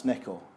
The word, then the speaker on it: Neko: So, well, that's Inu and that's Neko.